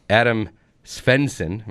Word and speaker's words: Adam Svensson: Large group at minus four, including the Canadian Adam Svensson. Am I saying that right, Kevin? Right? Sure. That's right.